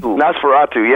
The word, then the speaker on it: Nosferatu: I wanted to ask you real quick, one of my most interesting albums of y'all's is, and I never pronounce it right, Nosferatu, the Dracula one? – Nosferatu, yeah, that was the opera we did about Dracula and vampires.